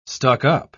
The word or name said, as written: stuck-up